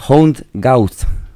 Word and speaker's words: Hound Gawd: Hound Gauth, que se escribe con, bueno, Hound, como suena, más o menos, y Gauth con G-A-W-D.